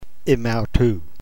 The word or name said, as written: emautou